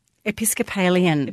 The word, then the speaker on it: Episcopalian: Oh no, but it, I th- I thought that was that that was very emotional for me, and I thought the es, how do you pronounce it? Episcopalian. Episcopalian. Episcopalian bloke from Chicago was an utter disgrace.